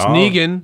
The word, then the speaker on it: Negan: It's Negan. – Negan, all right.